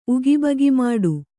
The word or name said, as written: ಉಗಿಬಗಿಮಾಡು